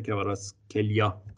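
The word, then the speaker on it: Kvaratskhelia: تلفظ درستش در واقع میشه کواراتس خیلیا،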